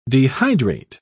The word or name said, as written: dehydrate